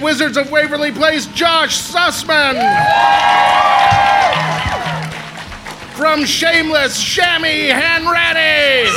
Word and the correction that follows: Sammy Hanratty: Let's do that one again, because I said it wrong. From Shameless, Sammy Hanratty! So sorry I called you Shammy Hanratty.